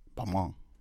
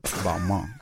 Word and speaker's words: Balmain: You're not saying it correctly. Balmain. Balmain.